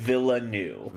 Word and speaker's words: Villeneuve: Just think about it phonetically. Yeah, Villeneuve. – Like the word villa. Like you go on vacation and you stay at a nice villa, and then just put the word new at the end of it.